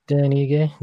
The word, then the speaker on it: Ige: Yeah, I think you called him Dan Aish. – I just realized earlier today that I said that wrong. – Yeah, Dan Ige.